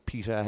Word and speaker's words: Pieta: Niall, the garden is based around, or it's on behalf of, I guess, PETA House, P-I-E-T-A, for those who are outside of Ireland. Can you explain to those at home, or for maybe who aren't aware, what is PETA House exactly?